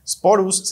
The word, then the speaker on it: Sporus: Sporus se escribe S-P-O-R-U-S.